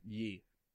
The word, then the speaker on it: Ye: Is it pronounced ye or yay? – Ye.